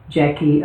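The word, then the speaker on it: Jacquie: I have two Twitter accounts, one for Blue Dreams, which is actually you can find me there as at Jackie underscore Blue, and my older account TS Jackie.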